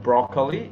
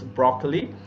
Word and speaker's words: broccoli: I've heard someone mispronounce it as broccoli. It's not broccoli, it's broccoli. Or in some cases, we reduce the middle syllable as broccoli.